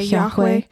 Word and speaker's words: Yahweh: Okay, Yahweh.